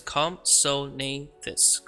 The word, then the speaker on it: Compsognathus: Right here it says, Compsognathus. Compsognathus. I say Compsognathus. I put the G in there. Probably the G is silent.